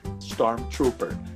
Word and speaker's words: Stormtrooper: A pronúncia correta é da palavra Stormtrooper. Stormtrooper, que eu não vou falar como foi pronunciado errado para não denunciar ninguém. Mas Stormtrooper.